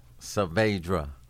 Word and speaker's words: Saavedra: Saavedra.